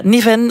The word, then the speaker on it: Neven: Want vanochtend heeft de Waalse minister voor Klimaat, Cécile Neven, of Niven, hoe spreek je het uit, van MR gereageerd ook op die rampen? – Ja, Niven was te gast bij Matin Première, bij onze collega's van de RTBF.